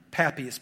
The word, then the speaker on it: Papias: Papias probably is how you say it, but Papias sounds more fun, all right?